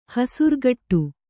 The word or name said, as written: ಹಸುರುಗಟ್ಟು